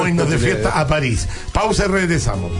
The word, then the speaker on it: París: ¿París o París? – París. – París, con acento en la I.